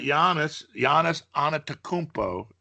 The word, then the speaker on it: Giannis Antetokounmpo: You know, last night Giannis Antetokounmpo is how you say it.